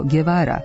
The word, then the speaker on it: Guevara: Glasovna grupa Gue izgovara se kao G, pa otud za kubanskog revolucionara koji se zove Che ne kažemo Guevara, nego Guevara.